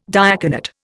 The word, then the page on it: diaconate: Indian